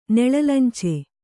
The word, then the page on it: ನೆಳಲಂಚೆ: Kannada